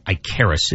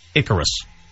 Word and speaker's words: Icarus: It's not Icarus. It's pronounced Icarus. It is the Greek god.